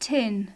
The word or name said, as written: tin